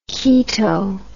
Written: chaeto

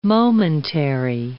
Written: momentary

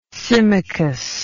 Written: symmachus

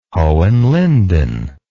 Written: Hohenlinden

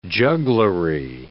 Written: jugglery

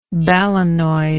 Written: balanoid

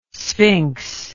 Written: sphinx

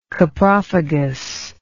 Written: coprophagous

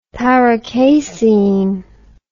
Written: paracasein

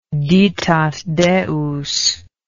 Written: ditat deus

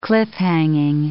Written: cliff-hanging

